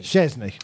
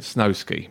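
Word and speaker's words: Szczęsny: So what is it? Is it Chesney? – Chesney. Wojciech Chesney.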